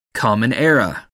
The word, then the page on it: common era: American English